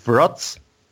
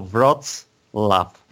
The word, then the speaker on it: Wrocław: It's it's Wroclaw and laugh, like laugh. Okay. – Oh. – So we pronounce it uh, a laugh in in the name of this uh, of the city. So Wrocław.